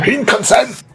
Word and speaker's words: Shinkansen: le Shinkansen, si je prononce ça bien. – Shinkansen